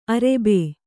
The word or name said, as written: ಅರಬ್ಬಿ